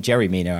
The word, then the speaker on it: Yerry Mina: We'll go to Jerry Mina, I believe it's actually pronounced. Wiser J is apparently in Colombian.